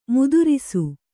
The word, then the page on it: ಮುದ್ರಸ: mudrasa; kannada